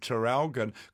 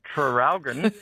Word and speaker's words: Traralgon: Seeing as you asked about the spelling of Traralgon, could you please pronounce the first R? – Oh, Traralgon. – Traralgon. – Traralgon? – Traralgon. – Traralgon. – Oh, yeah, and someone else, Maggie, says, don't we pronounce the first R in Traralgon anymore. Oh.